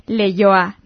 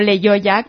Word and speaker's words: Leioa: y para aclararlo es Leioa, no Leioa, como lo habíamos pronunciado, así que un saludo para Leioa.